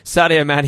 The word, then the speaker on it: Sadio Mane: Sadio Mane, yes, that is absolutely correct.